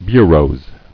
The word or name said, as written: Bureaux